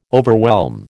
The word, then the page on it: overwhelm: American English